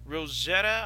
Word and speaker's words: Rosetta: Dick Rosetta? – John Mooney, along with Dick, R-O-S-E-T-T-A, Rosetta, I guess.